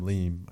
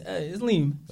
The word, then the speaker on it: LIIM: You said L-I-I-M? – Yeah, L-I-I-M, Liam. I think that's how you say it. He got some good videos. – I still don't know. I'm getting hip to him still. – Yeah, it's Liam.